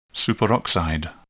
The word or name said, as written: superoxide